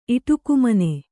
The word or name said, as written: ಇಟುಕುಮನೆ